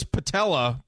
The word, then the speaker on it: patella: You just did it with patellar. It's patella. You're fine. – No, it's the patellar tendon, isn't it? – No, it's an A. – No, I thought it was patella, but you can call it the patellar tendon.